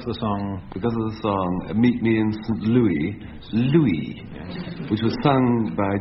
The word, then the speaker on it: St. Louis: I'm told it should be pronounced St. Louis, but never mind.